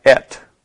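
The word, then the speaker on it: et (את): It's pronounced kind of like et.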